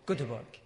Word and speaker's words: Göteborg: Göteborg, that's it.